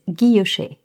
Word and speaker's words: guilloché: Today's Babble Bomb is guilloché, which is a type of decorative engraving.